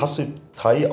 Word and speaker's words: Hasip Thai: Ja, der YouTube-Kanal heißt Hasip Thai, also Hasip Thai ausgesprochen, Hasip wie 50 auf Thailändisch.